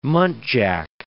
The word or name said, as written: muntjac